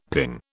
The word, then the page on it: ping: British English